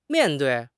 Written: mian4 dui4

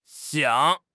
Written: xiang3

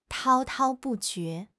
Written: 滔滔不绝

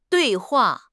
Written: dui4 hua4

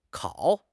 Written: kao3

